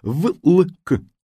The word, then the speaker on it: влк: По-видимому, в старославянском языке слово «влк» так и произносилось, как писалось «влк».